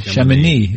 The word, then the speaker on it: Chamonix: Chamonix.